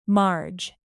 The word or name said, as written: marge